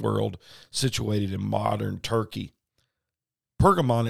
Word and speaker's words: Pergamon: Pergamon is the proper pronunciation of what we see in the King James as Pergamos.